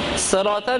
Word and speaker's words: صراط: یہاں میں صراط ہے صراط میں صاد ہے صاد اگر صاد آپ کے لئے معلوم ہے صاد تلفظ کریں اگر آپ کے لئے معلوم نہیں ہے سین تلفظ کریں کیونکہ صراط دو حالت صحیح ہے ہر دو حالت صحیح ہے صراط میں صرف نہ دوسرا الفاظ میں صراط میں دو حالت صحیح ہے صاد کے ساتھ سین کے ساتھ دونوں صحیح ہے